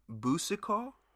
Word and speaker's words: Boucicault: By Dion Boucicault, is how you say it.